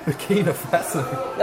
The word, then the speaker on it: Burkina Faso: So, Burkina Faso.